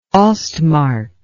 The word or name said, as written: ostmark